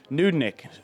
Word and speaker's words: Nudnik: The pronunciation has the double O, and there's an additional note you have here for me. The O-O is as in good, and so it's a nudnik.